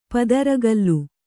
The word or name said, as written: ಪದರುಗಲ್ಲು